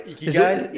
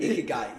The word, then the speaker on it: Ikigai: So there's a philosophy called Ikaji. I don't know if I'm saying it right. – Ikigai? – Ikigai. Yeah, there we go.